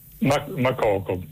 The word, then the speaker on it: Makoko: Mokoko con l'accento? – Makoko, sì, non so se è con l'accento finale alla francese, Makoko, però probabilmente siccome quello è un paese anglofono sarà in maniera diversa.